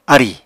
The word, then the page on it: arì: Apatani